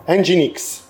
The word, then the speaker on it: Nginx: Il web server che hai nominato, NIGX, si pronuncia Nginx? – Nginx. – O bene. È Nginx in inglese.